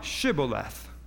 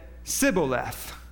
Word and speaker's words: Shibboleth: If he said, nay, look at verse 6, then said they, the Gileadites, unto him, say now, Shibboleth. And he said, Sibboleth, for he could not frame to pronounce it right.